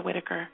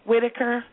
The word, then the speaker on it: Whitaker: My name, my first and last name is Michelle Whitaker. – Michelle Whitaker. – Michelle with the N. It's uh-huh. – N-I-C-H-E-L. I'm getting ready to spell it wrong. N-I-C-H-E-L-E. – Mm-hmm. – Whitaker, W-H-I-T-A-K-E-R.